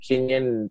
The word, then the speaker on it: Kinion: So TJ Kinyon, last name is spelled K-I-N-I-O-N.